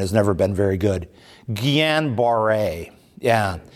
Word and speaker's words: Guillain-Barré: It's G-U-I-L-L-A-N, I think, or maybe it's A-I-N. But the double L there is sort of a Y sound, so Guyan. And then the B-A-R-R-E with an accent on the E. Guillain-Barré syndrome.